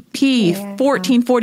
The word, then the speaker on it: P1440: And you as well, on top of being an Olympian, mom, Hall of Fame, you started your own company, P1440. Did I say that right? – You did. Platform 1440, but P1440.